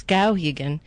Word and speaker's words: Skowhegan: happens to know it's Skowhegan and not whatever I said.